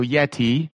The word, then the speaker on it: Yeti: So Yeti, which rhymes with Letty.